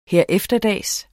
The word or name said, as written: herefterdags